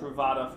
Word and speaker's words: Truvada: Truvada for PrEP.